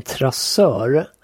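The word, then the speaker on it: traceur: Men eh, du nämnde något om, och nu vet inte jag om jag uttalar det här rätt. Vad är en trassur? En trassör är... En trassör, ursäkta mig.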